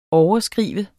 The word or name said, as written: overskrive